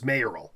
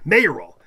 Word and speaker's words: mayoral: This really gets me because technically the best quote unquote pronunciation is mayoral. – Uh huh. – But I agree with mayoral is hard to say. Mayoral. Mayoral is easy to say. – Well, it's like mayonnaise, though. Mayoral.